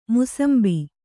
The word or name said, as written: ಮುಸುಂಬಿ